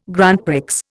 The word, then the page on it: Grand Prix: Indian